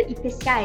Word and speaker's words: IPCA: E como que você comentou agora um pouquinho sobre IPCA, é isso mesmo? – Tô isso, falando certo?